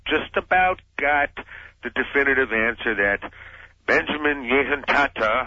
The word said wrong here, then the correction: Netanyahu: I still can't pronounce his name, could you give it to me? – Netanyahu.